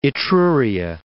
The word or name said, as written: Etruria